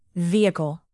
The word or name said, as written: vehicle